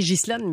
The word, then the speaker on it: Ghislaine: C'était aujourd'hui l'audience devant le tribunal donc de Ghislaine, de Ghislaine Maxwell, puisque ça s'écrit Ghislaine, mais elle le prononce Ghislaine Maxwell à New York.